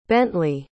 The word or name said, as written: Bently